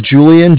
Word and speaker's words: Julian: Yeah, it's Julian, J-U-L-I-A-N, and I'm with Bank of Cardiff.